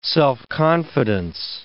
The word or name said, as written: self-confidence